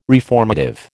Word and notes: reformative: American English

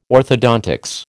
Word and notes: orthodontics: American English

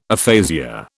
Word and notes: aphasia: British English

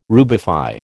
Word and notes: rubify: American English

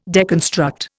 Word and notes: deconstruct: Indian

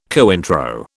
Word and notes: Cointreau: British English

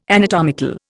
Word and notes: anatomical: Indian

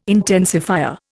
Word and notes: intensifier: Indian

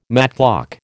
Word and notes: matchlock: American English

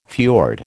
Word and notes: fiord: American English

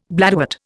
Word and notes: bladderwort: Indian

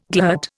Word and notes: glut: Indian

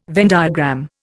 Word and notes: Venn diagram: Indian

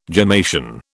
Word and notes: gemmation: British English

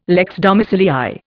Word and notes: lex domicilii: Indian

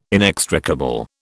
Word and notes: inextricable: British English

